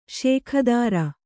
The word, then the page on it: ಷೋಕೀದಾರ: kannada